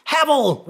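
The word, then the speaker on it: Haval: Havel, rhymes with gravel, is up 37%, while Great Wall was up by triple figures, 105%, or 354 additional vehicles.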